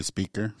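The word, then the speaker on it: Speicher: But so I think, and forgive me if I'm pronouncing this name wrong, Sienna, is it speaker or speecher? – Probably speaker. – Speaker. It's S-P-E-I-C-H-E-R.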